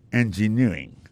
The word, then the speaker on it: engineering: Engineering, ça, l'accent tombé les mêmes sur avant, dernier syllabe là.